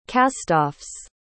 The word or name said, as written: castoffs